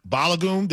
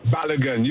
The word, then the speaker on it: Balogun: There's a young man, I believe you pronounce his name, Falarin Balogun. Did I say that right, or am I messing up that name? – Balogun, you got it right, Myron.